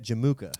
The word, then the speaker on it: Jamukha: Is that how you say it? Jamukha. – Jamukha is a good way to say it, yeah.